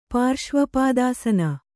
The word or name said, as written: ಪಾರ್ಶ್ವಪಾದಾಸನ